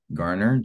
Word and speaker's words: Garner: I think the most important thing again is just to know how to spell my name, R-I-U-N, Ryan. It's pronounced Ryan, but R-I-U-N Garner, G-A-R-N-E-R.